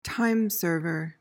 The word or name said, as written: timeserver